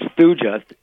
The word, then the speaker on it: Thuja: S-U-J-A, is that what you were saying? – No, it's Thuja, T-H-U-J-A, and it's an herb for nerve in the head, so it's really good to stop the migraine.